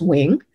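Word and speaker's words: Huynh: But yes, my last name is pronounced Wing. It is a very common Vietnamese last name. I sometimes tell people it's the Smiths of Vietnamese last names.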